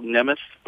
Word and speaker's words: Nemeth: Yep, and that's spelled N-E-M-E-T-H. So it sounds, it's spelled Nemeth, but it's pronounced Nemeth.